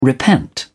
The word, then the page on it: repent: British English